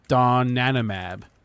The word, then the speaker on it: donanemab: It's donanemab, and that's your leader this morning.